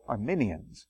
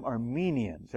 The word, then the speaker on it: Arminians: So the pronunciation is that person is Arminius, Jacob Arminius, and his followers are called Arminians. But we're not to pronounce them Armenians. Every now and then we accidentally do that.